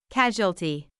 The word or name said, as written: casualty